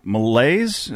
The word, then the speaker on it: MILLAZE: We are here with MIC artist out of Indiana, MILLAZE.